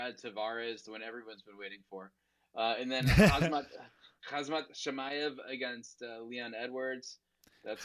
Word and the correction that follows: Khamzat: Khamzat, goddammit. Get his name right. – Khamzat, what did I say? – Khamzat, like hazmat suit.